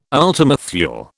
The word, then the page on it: ultima Thule: British English